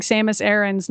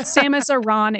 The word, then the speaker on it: Samus Aran: I remember when they finally put out the Other M trailer, I was like, yes, I have been pronouncing Samus Aran's name correctly. It's not Samus Aran, it's Samus Aran.